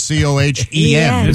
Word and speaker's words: Cohen: Cohen. – This that's confusing because no, not when it's spelled C O H E N. – It's definitely Cohen.